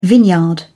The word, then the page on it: vineyard: British English